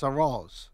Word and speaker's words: arroz: That's a rose. I'm pronouncing it wrong, but A-R-R-O-Z. That's different.